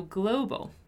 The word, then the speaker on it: global: And then we have the word global, which has this O sound.